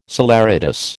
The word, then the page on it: saleratus: American English